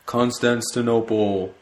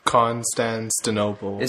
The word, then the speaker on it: Constantinople: It takes place in Constantinople. I said that wrong. – Constantinople.